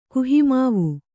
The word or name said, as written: ಕುಹಿಮಾವು